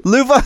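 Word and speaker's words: Lova: Love. – It's actually a Swedish name, Luva. – Luva, see, of course, see, yeah.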